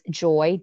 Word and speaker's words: Joye: It's joy with an E, .com. And then my Facebook page is Joy, JOYE.